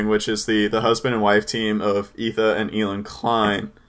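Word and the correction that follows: Ethan and Hila Klein: I just totally pronounced their name wrong. Ethan and Ela Klein.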